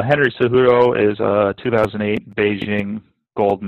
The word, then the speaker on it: Cejudo: So the next fight, Matt, is Henry Cejudo versus Dominic Cejudo. – Cejudo. Sorry. I should have known that J is silent.